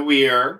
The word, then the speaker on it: Weir: So South Shore Theater Experience, The Weir, opening October 28th. – Spell The Weir, because it's spelled weird. – W-E-I-R. – W-E-I-R. – Like Bob Weir of the Grateful Dead.